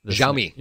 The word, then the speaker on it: Xiaomi: Xiaomi. – Xiaomi.